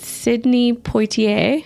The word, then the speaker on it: Sidney Poitier: In what movie did Sidney Poitier, did I say that right? – Mm-hmm.